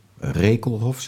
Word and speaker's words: Rekelhof: Rekelhof, zeg ik dat goed? – Ja.